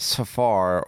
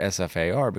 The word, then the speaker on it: SFAR: All right, this is brought to you by Ruger and the Ruger SFAR. You do not pronounce it Safar or Safar. It's really good, but it's SFAR, and it is a 7.62-308 gun, 10+20 capacity, Magpul stock, threaded.